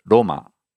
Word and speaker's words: Roma: So, for example, in Rome, as we say in English, Rome, in Japanese, they say Roma, which is closer to the original Italian of Roma.